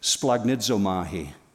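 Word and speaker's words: splanchnizomai: The first one is this, splagnizomahi. You've heard me pronounce it before because it sounds like an Italian pasta dish, right?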